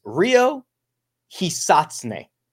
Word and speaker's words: Ryo Hisatsune: The guy that I'm referring to is Rio Hisatsune, is the way that I had a native Japanese speaker tell me that would be an acceptable way to pronounce his name.